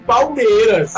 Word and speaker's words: Palmeiras: A pronúncia é essa, é isso mesmo, Palmeiras. Aí eu disse, ah, cara, pois pronto, agora você pode usar pra todos os árabes lá que a pronúncia é Palmeiras.